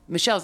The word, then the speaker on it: Mishell: My Mishell's actually my middle name, but it's spelled kind of weird. It's spelled M I Shell, so like my shell.